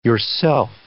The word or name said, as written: yourself